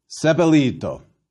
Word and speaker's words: sepelito: Thou shalt bury, sepelito. Thou shalt burn, urito. Why the difference in pronunciation? Fourth conjugation has a long I, and the third has a short I.